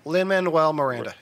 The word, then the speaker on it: Lin-Manuel Miranda: It's probably because it's Miranda, or how do you say his name again? Because I stutter over it all the time. – You got it. – Lin-Manuel Miranda.